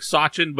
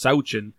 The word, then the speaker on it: Sauchin: It looks like Sauchin, but had him on the show. He says it's pronounced Sauchin, so we'll go with that.